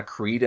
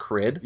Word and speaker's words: Creed: Like, I think it took me four books to actually start pronouncing it Tana Creed and not Crid.